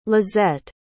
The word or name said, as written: lizette